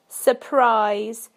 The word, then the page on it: surprise: British English